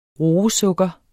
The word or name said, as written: roesukker